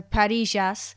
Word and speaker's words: parrillas: So with the two L's, it's a ja sound. So Parrillas.